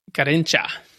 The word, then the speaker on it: Garrincha: That is Garrincha. However you pronounce it. – Yeah, I think you got it right.